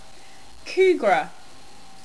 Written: kougra

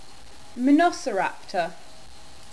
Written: Monoceraptor

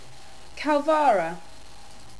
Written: kauvara

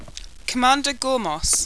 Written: commander gormos

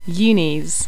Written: unis

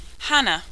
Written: hannah

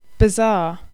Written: bazaar

